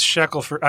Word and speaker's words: Shackelford: I knew Ted, but I wasn't sure how to pronounce it. What if... I knew this Shackelford. I didn't know Shackelford for sure.